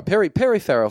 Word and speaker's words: Farrell: Perry Farrell from Jane's Addiction. That's how you pronounce his name.